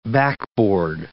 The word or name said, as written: backboard